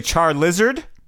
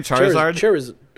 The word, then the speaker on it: Charizard: Charizard. – Charizard. – God damn. Is that his name? Charizard? – Wait, no.